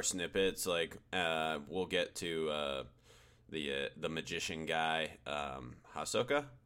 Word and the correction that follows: Hisoka: I think Hisoka is how it's pronounced.